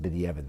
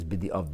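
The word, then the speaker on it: bedi'avad: Usually pronounced bidi'evad, but really it's bidi'ovad.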